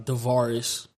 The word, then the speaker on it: Devirous: Um, but yeah, man, uh, Devaris, is how you pronounce my name, but you know, go by D makes it a little bit simpler.